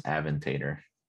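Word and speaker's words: Aventador: People would be like, oh, I want a Lamborghini, a Ventador. I'm like, good. – It's pronounced Aventator.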